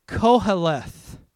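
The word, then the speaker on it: Koheleth (קהלת): The word for preacher is pronounced Koheleth. That's how you say the Hebrew word.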